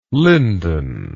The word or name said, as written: lindon